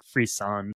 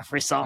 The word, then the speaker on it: Frisson: I think that it's like it comes from a French word, like Frisson, I think is roughly how you say it. Yeah, Frisson.